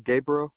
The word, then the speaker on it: Gabriel: Gabriel, Spell it.